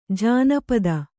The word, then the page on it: ಜಾನಪದ: jānapada